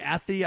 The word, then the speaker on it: Athey: A-T-H-E-Y. Athey, I guess is how you say it.